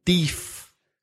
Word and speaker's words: deaf: Corn beef sounds like deef, but deef doesn't mean anything. But in Belfast, people say deef to mean deaf.